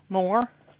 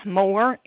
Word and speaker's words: Moore: Paula Moore. – Okay, can you spell that, ma'am? – Paula, P-A-U-L-A, Moore, M-O-O-R-E.